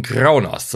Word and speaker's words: Kronos: Captain Kronos, so genau so spricht man den immer aus, immer schön so R-Rollen.